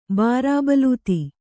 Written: ಬಾರಾಬಲೂತಿ

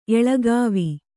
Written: ಎಳಗಾವಿ

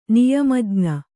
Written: ನಿಯಮಜ್ಞ

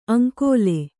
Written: ಆಂಗುಲಿ

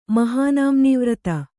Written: ಮಹಾನಾಮ್ನೀವ್ರತ

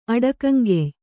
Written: ಅಡಕಂಗೆಯ್

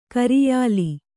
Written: ಕರಿಯಾಲೆ

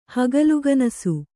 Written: ಹಗಲುಗನಸು

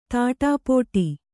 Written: ತಾಟಿಪೋಟಿ

